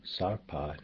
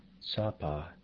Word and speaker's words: sarpa: Others like trangmo, cold, sarpa, new, and kama, minute or star, may be pronounced without the R sound in the first syllable, trangmo, sapa, and kama.